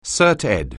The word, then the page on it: CertEd: anglais